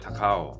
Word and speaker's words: Takao: 好，在一九二零年，也就是大正九年的时候，当时的第八任总督田健治郎将高雄改制为一个州，把这个地名的发音打告，呃，转化为一个日语的谐音，把它转化为 Takao，用 Takao 的汉字写成高雄。